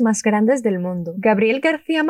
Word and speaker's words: García: García. Lleva tilde la I, por eso Ia.